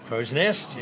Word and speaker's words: Crow's Nest: I've got to say that right. Crow's Nest. – Crow's Nest. Yes. You've got a Crow's Nest.